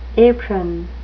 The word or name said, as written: apron